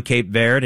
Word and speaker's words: Cape Verde: Uruguay is going to be taking on Cape Verde, and yes, that's how you pronounce it in English.